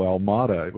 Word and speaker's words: Almada: It should be a simple Almada, which is a Portuguese name. But you get Alameda, Alameda, Alamoto. Do I look Japanese? – You know, too tall for that one.